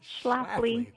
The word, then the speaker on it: Schlafly: That's a tough last name to pronounce. – Schlafly, yes. – Yeah, there you go.